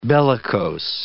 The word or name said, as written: bellicose